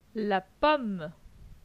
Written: La pomme

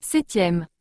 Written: 7e

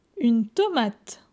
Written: Une tomate

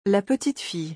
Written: La petite fille

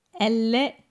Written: Elle est

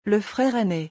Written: Le frere aine

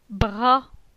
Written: Bra

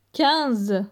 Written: 15

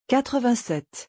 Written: 87

